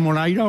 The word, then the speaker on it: Moraira: Lo he dicho bien, ¿veraneaba usted en Moraira o no? – Sí, lo ha dicho usted exactamente como es.